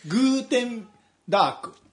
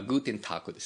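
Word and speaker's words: Guten Tag: グーテンダーク、ん、まあ、グーテンタークですよね。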